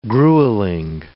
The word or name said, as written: grueling